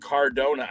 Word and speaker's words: Cardona: Matt Cardona.